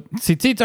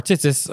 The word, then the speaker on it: tzitzit: So tzitzit or tzitzis, as different pronunciations, are a religious garment that are worn by Orthodox men.